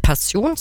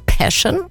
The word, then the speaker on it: Passion: Also englisch ausgesprochen nicht Passion, sondern Passion. Das ist im Vorfeld tatsächlich falsch ausgesprochen.